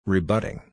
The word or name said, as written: rebutting